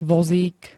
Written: vozík

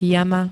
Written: jama

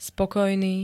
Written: spokojný

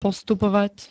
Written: postupovať

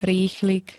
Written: rýchlik

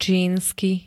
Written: čínsky

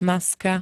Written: maska

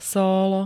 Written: sólo

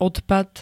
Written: odpad

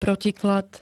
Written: protiklad

